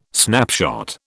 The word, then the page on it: snapshot: British English